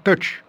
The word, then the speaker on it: Tush: Oui, ça, c'est Tush. Mais enfin, je ne sais pas, hein, c'est Dusty Hill, whose pronunciation of Tush makes it sound more like touch.